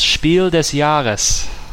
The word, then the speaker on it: Spiel des Jahres: I think I pronounced that incorrectly. – That is horrible pronunciation, thank you. – You're welcome. – It's, okay, just for prosperity of the title, it's Spiel des Jahres.